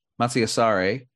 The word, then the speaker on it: Masi Asare: Paradise Square, Jason Howland, Nathan Tyson, and Masi Ara. Oh, man, I hope I'm not pronouncing the name correctly. Masi Asare.